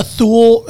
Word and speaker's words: Thule: No, no one says that. Everyone says Thule. – Okay, just Thule.